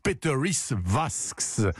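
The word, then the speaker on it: Pēteris Vasks: il s'appelle Peteris Vask, prononcez toutes les lettres, hein, donc Peteris Vasks,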